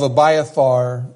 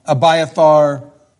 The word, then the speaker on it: Abiathar: Some people call him Abiathar. You can call him whatever you want. Abiathar, I think, is a little closer to the correct pronunciation, but most people here in the U.S. just say Abiathar the priest.